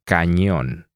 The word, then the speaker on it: cañón: It is pretty nasal, very similar to the sound you find in canyon. – ¿Y cómo se dice canyon en español? – Cañón.